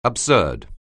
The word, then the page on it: absurd: anglais